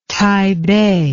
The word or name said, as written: taibei